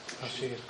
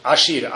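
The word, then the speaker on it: Ashir (עשיר): Eu lembrei de uma coisa, como se fala rico em hebraico? Ashir. – Ashir.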